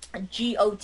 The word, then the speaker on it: Gottlieb: I guess Hermes Gutlove. Mm-hmm. I can't pronounce the name. Excuse me. Gottlieb.